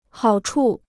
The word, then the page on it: 好处: hǎo chu